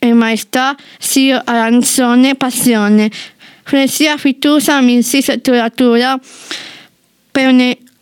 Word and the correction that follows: Peonia: come si pronuncia? Peonia. – Peonia.